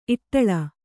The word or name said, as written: ಇಟ್ಲ